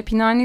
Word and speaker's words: Pinhani: Pinha, pinhani diyor okunuyor o Evet galiba.